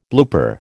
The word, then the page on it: blooper: American English